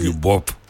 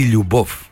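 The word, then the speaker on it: Λιουμπόφ (любовь): Έτι Λιουμπόφ. Έτι Λιουμπόφ. Θα πει θυμίσω εκείνο τον έρωτα, κύριε Χρυσούλα. – Περίμενε. Ζαπόμνη έτι Λιουμπόφ. – Με β στο τέλος, αλλά προφέρεται F.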